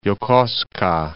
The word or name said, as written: Yokosuka